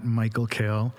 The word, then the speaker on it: Mikhael Kale: Again, Instagram, and that's at Michael Kale, and that's spelled M-I-K-H-A-E-L-K-A-L-E.